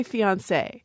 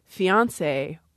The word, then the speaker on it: fiancé: I say fiancé, but students told me recently that that sounded very fancy to them and that they had moved the stress forward to fiancé or fiancé.